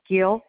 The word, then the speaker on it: Gill: First name is C-D-E-E Gill, G-I-L-L.